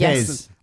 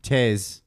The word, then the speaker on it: Taix: T-A-I-X, the restaurant. – That's Tez. – Tez? Been there since 1962. – Wait, say it again. – Tez. T-A-I-X. French place.